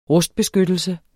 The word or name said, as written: rustbeskyttelse